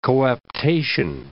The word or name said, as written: coaptation